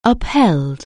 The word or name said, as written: uphold